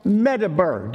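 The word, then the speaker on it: medaber (מְדַבֵּר): Midbar is Hebrew for desert, whereas speaking, it's just a couple of different letters in it, and that's pronounced medaber. Do you see how close? Midbar and medaber. And medaber is M-E-D-A-B-E-R.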